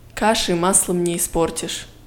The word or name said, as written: каши маслом не испортишь